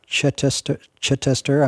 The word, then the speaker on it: Chittister: You interviewed Joan Chittister, am I saying it right? – Yes.